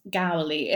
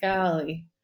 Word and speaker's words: Gowley: It's Gowley. It has like a kind of silent R, basically. But it's like, you kind of say it like a tiny bit. It's like Gowley, if that makes sense.